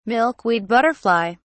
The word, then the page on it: milkweed butterfly: British English